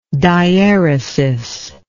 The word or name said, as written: dieretic